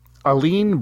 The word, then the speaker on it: Allene: How would you say her name? A-L-L-E-N-E. – You, like, smoothed through it very well, Aline. I actually, all three times I watched this, went, how do you pronounce that? yeah, let's say Aline. – Aline? I like it because it's kind of like Eileen, but it's with an A.